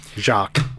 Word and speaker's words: Jacques: I never knew how to pronounce that. – Jacques. – Jacques? How dare you? That's. Oh, sorry. Jacques? – Jacques. – Mm-hmm. Okay, cool. – No S sound on the end.